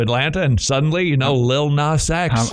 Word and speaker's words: Lil Nas X: Yeah, Lil Nas X.